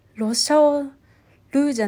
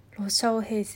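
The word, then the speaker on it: 羅小黒: あ黒って書いてヘイって読むんだねへえロシャオルーじゃないんだこれロシャオヘインキ。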